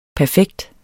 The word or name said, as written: perfekt